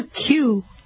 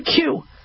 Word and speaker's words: queue: They go, you are the fourth one in the queue. Like, in the queue, I'm not in the, what's a queue? Letter Q, I guess it's spelled Q-U-E-U-E.